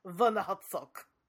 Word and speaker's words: Werner Herzog: You have to say it like this, Werner Herzog. – Oh, Werner.